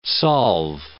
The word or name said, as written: solve